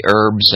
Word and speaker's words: herbs: You know, I always have like natural, you know, healthy lozenges that have the honey and have the herbs and all that. I love when Martha Stewart says herbs. No, it's a silent H.